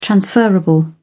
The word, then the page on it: transferable: British English